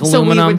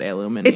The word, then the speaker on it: aluminium: It's actually spelled different in Australia, and that's why it's pronounced aluminium. – I listen to people in England call it aluminium.